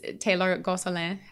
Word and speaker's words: Taylor Gonsoulin: And we are joined here today by one of my very good friends, Taylor Gonsoulin, to give the little Cajun pronunciation in there.